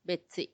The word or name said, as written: betsi